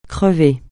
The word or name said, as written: crever